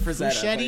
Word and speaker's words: Frazetta: You called him Frank Fuschetti. It's Frazetta. Yeah, I mean, it's probably Frigetta or something, but it's an A.